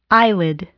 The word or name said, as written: eyelid